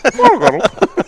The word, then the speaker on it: Throttle Grotto: I started Throttle Grotto.